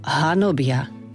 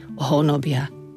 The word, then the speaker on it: hanobia: Z hľadiska výslovnosti je potrebné zrozumiteľne povedať slovo hanobia. Je niečo úplne iné, keby sme povedali honobia. Takže pozor aj na tú dôslednú výslovnosť.